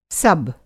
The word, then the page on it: सब्: sub